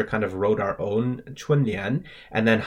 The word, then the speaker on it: 春联: So another thing that happens during this period of 大扫除 is people hang up these things called 春联 or spring couplets. So that's spelled C-H-U-N in the first tone, 春, and then L-I-A-N in the second tone, 年, literally meaning spring and couplet.